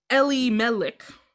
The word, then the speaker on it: Elimelech: The pronunciation Elimelech sounds like the Hebrew words for "my God is king."